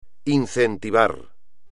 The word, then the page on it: incentivar: espagnol